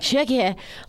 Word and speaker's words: Choceur: Shockier. – Spell it for us. – C-H-O-C-E-U-R. It's from Aldi, right? – Yeah, right. – Shockier.